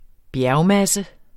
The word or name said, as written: bjergmasse